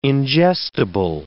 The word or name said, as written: ingestible